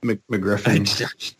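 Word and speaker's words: MacGuffin: McGuffin. – They like to put an R in there, even though there's no R.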